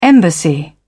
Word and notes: embassy: British English

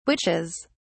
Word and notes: witches: British English

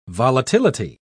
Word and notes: volatility: British English

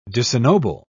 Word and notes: disennoble: British English

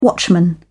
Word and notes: watchman: British English

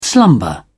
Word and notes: slumber: British English

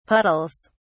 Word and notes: puddles: British English

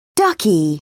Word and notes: ducky: British English

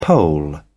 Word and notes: poll: British English

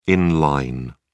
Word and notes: in line: British English

